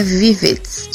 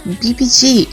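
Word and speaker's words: VIVIZ: que todo mundo pensava que se pronunciava assim mesmo, mas na verdade a pronúncia de Vivets é BBG, não apenas porque o alfabeto coreano não tem a letra V, mas também porque é um jogo de palavras com o nome das três.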